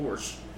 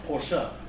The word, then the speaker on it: Porsche: I'm like, it's a Porsche. – No, it's Porsche. – What? – Yes, that's how you say it. It's Porsche. – Is it Porsche? – Yes.